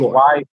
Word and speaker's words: Yegor: His first name is Yegor, because it's Yegor. Y-E-G-O-R, and his last name is Rykov. So you pronounce it with an E, not an I for the Y.